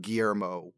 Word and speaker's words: Guillermo: Well, William is like the English version of Guillermo.